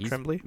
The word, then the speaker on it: Tremblay: Jacob Tremblay.